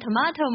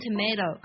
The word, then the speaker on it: tomato: 你会读成 tomato 吗？还是会读成 tomato？其实这两个音都是对的，一个是英式的发音，一个是美式的发音。